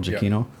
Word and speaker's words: Giacchino: I wish they would have had Michael Giacchino. Is that how you say it? Nick? Yeah. Michael Giacchino.